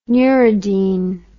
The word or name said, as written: neuridine